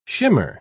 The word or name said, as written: shimmer